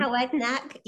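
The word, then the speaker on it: Towednack: Is that the right pronunciation? – Tywednach, yes.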